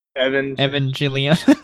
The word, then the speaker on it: Evangelion: Evangelion. – Yeah. Oh, okay. It's a hard G, then.